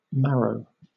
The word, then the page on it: marrow: British English